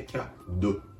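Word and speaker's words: cadeau: キャド CAD ですね。あえてで細かいことをやしていただくと、お、これは日本語のおよりも多少口をすぼめて CAD、ドと口を丸めた、すぼめたおの発音にすると、この CAD の発音になります。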